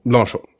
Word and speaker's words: Blanchot: Och det är Horace Engdahl som introducerar Maurice Blanchot. – Blanchot.